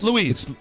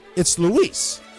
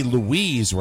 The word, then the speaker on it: Luis: It wouldn't be Louise, Rob. You gave me a L-O-U-I-S-E. That is exactly how he wrote it. – How do you spell it? – Luis. – It's L-U-I-S. It's Luis.